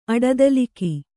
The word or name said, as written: ಅಡದಲಿಕಿ